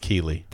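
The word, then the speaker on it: Keighley: It's pronounced Keeley. – Thank you. It's Jeff Knightley Keeley. – It always looks like Knightley, but it does. – It's Keeley. – Yeah, there's no N there.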